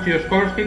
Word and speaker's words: Tsiolkovsky: Tsiolkovsky. – ¿Aceptamos Tsiolkovsky como pronunciación correcta, sí, del ruso? Sí.